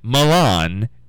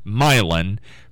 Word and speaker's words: Milan: So, if you're listening to the news report, I'm saying Milan. It's actually Milan.